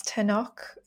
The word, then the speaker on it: Tenoch: So, best friends, working class Julio and upper class Tenoch. Is that how you say it? – Yeah.